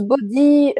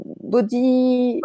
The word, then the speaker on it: body: Euh, ça se prononce body, body. Body hypnosis. ouais, c'est comme la contraction, la contraction de body hypnosis.